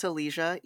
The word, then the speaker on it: Elysia: It's hard to pronounce. But I always say, if you can say Asia, you can say Elysia.